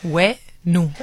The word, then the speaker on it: Huenu: The H confuses people. In Spanish, the H is not pronounced. You know, like the French is hello, hello. It's because the H is not pronounced in French. So ignore the H, and then it's Huenu.